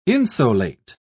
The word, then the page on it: insolate: British English